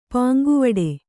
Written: ಪಾಂಗುವಡೆ

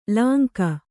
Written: ಲುಂಗ

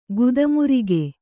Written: ಗುದುಮರಿಗೆ

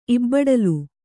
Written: ಇಬ್ಬುಡ್ಲು

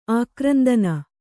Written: ಆಕ್ರಂದನ

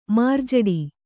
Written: ಮಾರ್ಜಡಿ

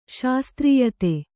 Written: ಶಾಸ್ತ್ರೀಯತೆ

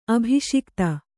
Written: ಅಭಿಷಿಕ್ತ

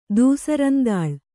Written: ದೂಸರಂದಾಳ್

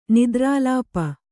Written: ನಿದ್ರಾಲಾಪ

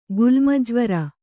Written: ಗುಲ್ಮಜ್ವರ

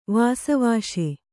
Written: ವಾಸವಾಶೆ

